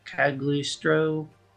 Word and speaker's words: Cagliostro: Cause first he helped out with the Castle of Cagliostro. That's how you say it.